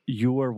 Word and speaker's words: your: You're welcome. So same pronunciation. I'm saying your, just like more, but with the y, y sound at the beginning your, you're welcome.